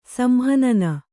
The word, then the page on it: ಸಂಹನನ: samhanana